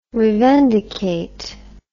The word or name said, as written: revendicate